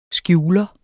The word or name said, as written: skjuler